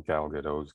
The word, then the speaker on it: Gal Gadot: Gal Gadot's character. I'm never sure if I'm pronouncing her name right, but yeah, Gal Gadot.